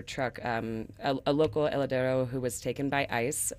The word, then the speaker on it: Heladero: Heladero.